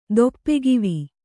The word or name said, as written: ದೊಪ್ಪೆಗಿವಿ